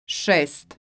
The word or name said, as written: sest